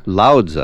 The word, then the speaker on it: Lao Tzu: Lao Tzu.